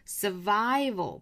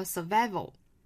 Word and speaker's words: survival: 那很多同学可能会读错 survival 这个单词，注意它后面这个是一个双元音 survival，不要读成了 survival value。